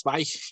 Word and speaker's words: Zweig: Eh, lamentablemente yo de, de, de, Stefan Zweig, yo le digo soy yo sé que es Zweig, y aquí los puristas me, me, corrigen cuando lo pronuncio mal.